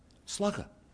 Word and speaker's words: slacaire: And there in the dictionary is a word spelled S-L-A-C-A-I-R-E, pronounced slugger.